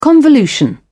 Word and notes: convolution: British English

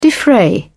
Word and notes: defray: British English

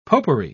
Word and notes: popery: British English

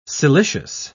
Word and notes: silicious: British English

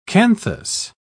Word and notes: canthus: British English

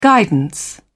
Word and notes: guidance: British English

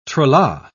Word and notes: tra-la: British English

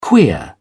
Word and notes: queer: British English

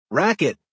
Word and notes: racquet: American English

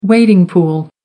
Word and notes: wading pool: American English

